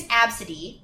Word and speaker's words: Abcde: Her name is Absidy. It's spelled A-B-C-D-E.